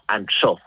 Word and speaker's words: Ansó: Y el otro pueblo, el rival, pues sería Ansó, con acento en la O, no Ansó, Ansó, que es un pueblo pues ahí escondido casi entre las montañas.